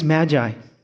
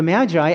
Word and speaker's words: magi: So I did a lot of research here, and I beheld these magi. The magi actually is an untranslated word. It's never been transliterated in any other way. And the word is actually pronounced magi. Magi.